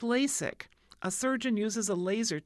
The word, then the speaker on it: LASEK: Next is LASIK. It's spelled L-A-S-E-K. The other one was I-K. This one, L-A-S-E-K, LASIK, stands for laser epithelial keratomalusis.